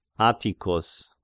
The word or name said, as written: Atticus